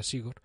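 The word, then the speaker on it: Zigor: Pero este año he descubierto también a Sigor Madaria, eh, se escribe Sigor con Z, en, en, euskera se pronuncia Sigor, que su podcast latiendo a mí me conquistó con el episodio 12, el de sueño y salud cardiovascular.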